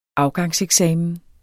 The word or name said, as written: AFGANGSEKSAMEN